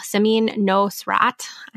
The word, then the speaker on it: Samin Nosrat: Heat by Samin Nora. Wow, Samin Nosrat. I totally said that wrong.